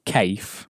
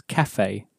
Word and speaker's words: café: That e, in English as well, gives with the accent on, gives the sound a. Because we don't pronounce café as cafe, which we would if there was no café e on the end.